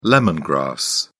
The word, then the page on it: lemon grass: British English